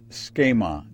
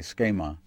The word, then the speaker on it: schema: And when we get there, we discover that it's schema. That's an S, that's a CH, that's an E, a long E, that's an M, and that's an A, schema.